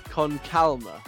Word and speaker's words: Con Calma: You're calling that song Con Karma as if it's like chili con carne. – Yeah. It's how's it supposed to be pronounced? – Calma. It's an Calma. L, not an R. – Con Calma.